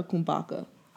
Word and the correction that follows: kombucha: I I thought it was kombucha.